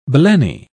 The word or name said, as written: blenny